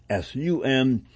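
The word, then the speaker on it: S-U-M (СУМ): The association is known by the Ukrainian acronym CYM, pronounced S-U-M, of its name Shtilka Ukrainsky Molody, along with the Ukrainian scouting movement PLAST, P-L-A-S-T.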